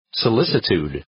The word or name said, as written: solicitude